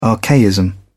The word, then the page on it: archaism: British English